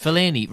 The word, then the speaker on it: Fellaini: Fellaini. Rhymes with Delaney. – The Belgian of being a magical player. – I don't think it really does rhyme with Delaney. I think it's probably... – the pronunciation that we've settled on.